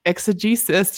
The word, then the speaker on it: exegesis: exegesis. Did I say that right? – Yeah, that's funny. yeah. – and visual exegesis in the creation of sculptural programs.